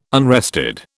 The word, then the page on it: unrested: British English